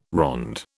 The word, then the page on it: ronde: British English